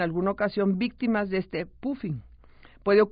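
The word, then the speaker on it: phubbing: Y bueno, la otra práctica relacionada con las nuevas tecnologías es el famoso poofing. Yo les digo que se escribe P-H-U, viene de W-I-N-G,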